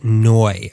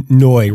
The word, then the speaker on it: Ngoy: Enter Ted Ngoy. – I believe I'm pronouncing that right. Yeah. N G O Y.